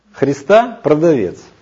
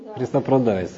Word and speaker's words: христопродавец: «Христопродавец», А почему продавец, а не продавец? Вы думали? Христа продавец. Почему ударение не там? Не замечали? Христа продавец.